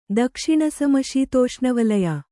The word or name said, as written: ದಕ್ಷಿಣಸಮಶೀತೋಷ್ಣವಲಯ